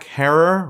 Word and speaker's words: Herer: So Abdul and I in Los Angeles did the story of Jack Herer, Rhymes with Terror, and how he green-pilled the world.